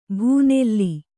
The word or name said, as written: ಭೂನೆಲ್ಲಿ